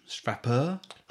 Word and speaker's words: Frappart: Sorry, um, Stephanie Frappart, and I'm going to say that wrong because she's French. – Frappeur. Frappeur. Frappeur.